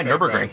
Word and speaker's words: Nürburgring: And the Nürburgring record, I said that right, Nürburgring? – Yeah, okay, Nürburgring.